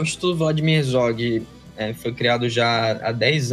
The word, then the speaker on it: Vladimir Herzog: Como é que pronuncia? – Isso, é isso mesmo. É Instituto Vladimir Herzog.